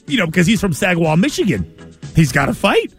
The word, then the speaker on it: Saginaw: Saginaw.